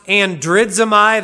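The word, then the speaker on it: andridzomai (ἀνδρίζομαι): The problem is that the Greek word itself, the imperative, is pronounced andridzomai.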